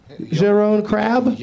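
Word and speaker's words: Jeroen Krabbé: Jeroen Krabbe. Is that how you pronounce that, Leonard? Jeroen Krab? – Jeroen. – Jeroen Krab? – Yeah.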